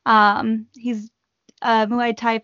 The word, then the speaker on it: Muay Thai: And so my cousin Benson, he's a Muay Thai fighter. I'm not, probably not saying, did I say that right? – Yeah, you did. Yeah, it's amazing.